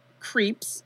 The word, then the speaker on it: Krieps: And the mother, Priska, who is played by Vicky Creeps from Phantom Thread. Did you see Phantom? I feel bad. So, it's spelled Creeps, but with a, it's like K-R-I-E-P-S. Like, that's her stage name.